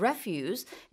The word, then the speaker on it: refuse: 우리가 거절하다 할 때 refuse랑 spelling은 같아요. R-E-F-U-S-E. 그러나 발음이 refuse라고 해서 쓰레기란 뜻입니다.